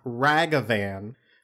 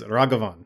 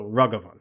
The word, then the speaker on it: Raghavan: He posted a great video with the pronunciation because it is not Ragavan. It is Raghavan, or Raghavan. When you're dealing with names from languages that are not English or not even rooted in Latin, I'm thinking specifically about like Hindi or various other Sanskrit derivative languages, or even like Japanese or something. The vowels are usually going to be pronounced the same in a name. So it's the A's in this are pronounced like with an emphasis on the first syllable.